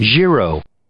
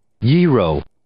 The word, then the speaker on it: gyro: How about G-Y-R-O? – Euro? – Gyro. – Gyro. – Aha! – Gyro. – That's something. – I say euro. – I say euro too, but if you go to the Merriam-Webster pronunciation, that's where... Okay, let's... – Giro. Yero. – So these are all correct.